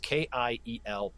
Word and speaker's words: Kiel: But it's it's just K I E L P H E G L E Y.